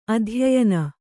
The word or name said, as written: ಅಧ್ಯಯನ